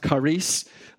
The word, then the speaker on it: charis: For you that might be new to Charis New Testament Church and not really know what charis means, it's actually pronounced charis, and that's where word we get charisma from.